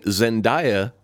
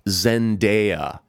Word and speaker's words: Zendaya: We have been saying Zendaya when apparently the proper pronunciation of the girl's name is Zendaya.